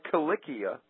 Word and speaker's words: Kilikia: And it's a hard C. And that's so evident in words like kilikia, mm-hmm. – where the Greeks spelled it with nothing but K's.